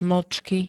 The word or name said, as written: mlcky